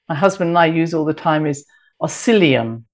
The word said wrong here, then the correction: Oscillococcinum: And I'm always pronouncing it wrong, and I'm just holding it up for those who are on the video to see. Oscillinum, I should call it. And I will just spell it O-S-C-I-L-L-O-C-O-C-C-I-N-U-M.